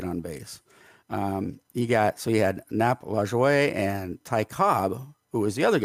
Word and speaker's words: Lajoie: and his last name is Lajoie. Did I say it right, do you think? – That's Nap Lajoie? That's how I say it.